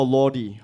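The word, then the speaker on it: Lorde: Is that how you pronounce it, or Lordy? How do you pronounce it? Is it Lord? – It's Lord.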